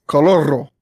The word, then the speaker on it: Coloro: How do you pronounce Coloro? Coloro. How do you pronounce that? – Like you just did. You got to roll the R. Coloro.